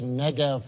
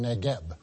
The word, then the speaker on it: Negev: And why do I say Negev rather than Negeb? Well, it's Hebrew. And the B is said like a V.